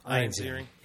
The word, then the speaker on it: Ian Ziering: Ian Ziering. – Ian. – Did I say his name right? – Ian. No. Ian. – Ian Ziering.